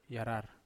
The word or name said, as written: arar